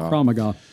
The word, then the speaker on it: Krav Maga: Am I saying that right? Krav Maga. – Krav Maga.